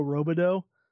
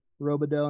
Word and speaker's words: Robidoux: One of the games I do is called Save Some Dough, but it's spelled like Robidoux, and it's got his face on there.